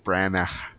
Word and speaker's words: Branagh: Branagh. – I thought there was a G in it. – There is. Branagh. – That's how you say it? – Branagh. – No, some letters are silent.